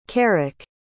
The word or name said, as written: carrick